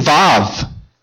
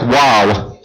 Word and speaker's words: Vav: Okay, I'm looking for Team Vav. W-A-W. It looks like W-A-W. It looks like wow. Okay? But it's pronounced Vav.